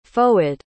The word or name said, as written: Fouad